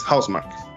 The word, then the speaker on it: Housemarque: La pronunciación correcta declarada el 2014 por Santa Claus en un video de Housemark sacó que mencionar que la pronunciación correcta es Housemark, ya que viene de los nombres de dos estudios que se fusionaron creándolo de Terramark y Bloodhouse.